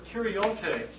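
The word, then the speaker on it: kuriotes (κυριότης): Now, this is the Greek word kuriotes. Kuriotes, spelled K-U-R-I-O-T, long E-S.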